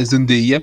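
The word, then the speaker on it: Zendaya: E a Zandaia é uma puta. Na verdade, o nome dela é Zandeia, porque o vídeo dela ensinando como falar o nome dele, dela. E é uma puta série. E a Zandeia é uma puta atriz.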